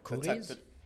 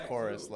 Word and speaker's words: Chorus: Not Cooties. – It's pronounced Chorus, like singing.